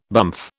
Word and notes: bumf: British English

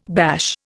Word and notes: bash: Indian

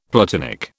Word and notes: platinic: British English

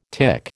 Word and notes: tic: American English

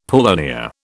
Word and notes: paulownia: British English

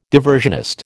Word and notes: diversionist: American English

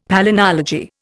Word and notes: palynology: Indian

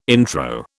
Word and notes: intro: British English

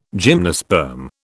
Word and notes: gymnosperm: British English

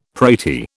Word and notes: pratie: British English

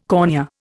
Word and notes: cornea: Indian

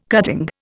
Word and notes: cutting: Indian

